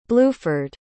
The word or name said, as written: bluford